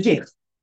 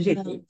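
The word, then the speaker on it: ŻIH: The Jich. The Jich.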